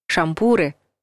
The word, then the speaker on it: шампуры: Ударение во всех формах существительного «шампур» падает на «у», поэтому правильно говорить «шампуры», «жарить на шампурах», «есть с шампура».